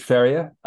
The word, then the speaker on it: Ferrier: Yeah, George Ferrier, like a horse farrier, you know.